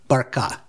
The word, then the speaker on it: Barkha: Barkha.